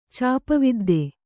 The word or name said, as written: ಚಾಪವಿದ್ದೆ